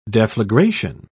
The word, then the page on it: deflagration: British English